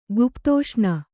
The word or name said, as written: ಗುಪ್ತೋಷ್ಣ